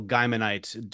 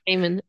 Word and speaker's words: Gaiman: What is a Neil Gaimanite? Do you have Gaiman. – a? God damn it! I always say it wrong. – Gaiman.